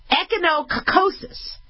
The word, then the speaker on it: echinococcosis: So she ended up having cystic echinococcus. Oh, sorry. Okay, I got to pronounce this right. Echinococcosis, also known as hidatidosis.